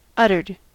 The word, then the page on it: uttered: American English